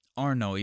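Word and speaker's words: Arnaud: It's pronounced Arno, even though the last letters are A-U-D, so they're silent, I guess.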